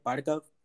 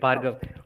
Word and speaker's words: Bhargav: Bhargav? – Yes, you're saying it right, Bhargav.